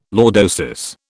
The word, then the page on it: lordosis: British English